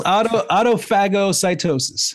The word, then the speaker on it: autophagocytosis: autophagocytosis. If I'm saying that correctly, Autophagocytosis. – There we go.